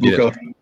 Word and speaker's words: Bucha: Is it pronounced Bucha or Buka? I'm not correcting you. I'm asking. – I'm an American. I call it Bucha. Some people call it Buka. We'll see. I've heard others.